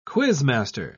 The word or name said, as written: quizmaster